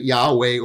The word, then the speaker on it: Yahweh: And YHWH, and we've supplied some vowels in there so we can pronounce it, Yahweh or Jehovah.